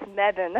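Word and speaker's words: Mebane: We have on the line from Medane, North Carolina. Am I pronouncing that correctly? – No, actually, it's Medin.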